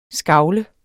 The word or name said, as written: skagle